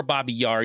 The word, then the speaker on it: Babi Yar: Now, if anybody knows anything about Babi Yar or Babi Yar, you can pronounce it either way.